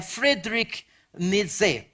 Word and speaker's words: Friedrich Nietzsche: Ini bacanya Friedrich Nietzsche, orang Jerman. Jadi ini jangan dibaca pakai bahasa Inggris ya. Kalau dibaca pakai bahasa Inggris, nama depannya agak lucu. Friedrich.